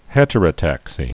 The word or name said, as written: heterotaxia